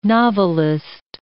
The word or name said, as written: novelist